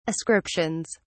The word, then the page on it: ascriptions: British English